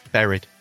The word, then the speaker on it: buried: No, you say it like straw buried. Yeah, it's an E. Buried.